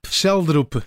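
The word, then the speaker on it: Schjelderup: Uh, eh, Sheldrup? – Sheldrup.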